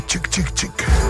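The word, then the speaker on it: !!!: A podle všeho se to, tak jak jsme se shodli s panem Googlem, vyslovuje čik, čik, čik. Ale členové kapely tvrdí, že se může vyslovit jakákoliv hláska, která zní tak blbě jako třeba čk.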